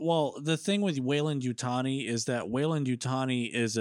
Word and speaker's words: Yutani: Did Wayland, what is it, Yutari, how do you say Yutani.